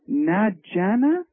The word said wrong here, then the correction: Najida: You're close, but it's Najida. – I'm sorry. Say it again, hon. Can you speak up? – Najida.